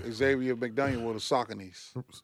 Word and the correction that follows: Saucony: Saucony. Please say it correctly.